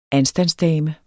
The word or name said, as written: ANSTANDSDAMER